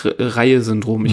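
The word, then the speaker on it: Reye-Syndrom: Dieses Syndrom nennt sich Reihe-Syndrom. Ich glaube, so spricht man das aus. Ne? Ja.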